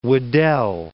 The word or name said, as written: Weddell Sea